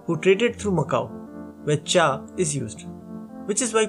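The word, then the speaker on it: cha (茶): The other is some variation of cha, like chai in Hindi, zai in Russian, or kai in Turkish. Both versions come from the same Chinese character. In Mandarin and Cantonese, it is pronounced as cha.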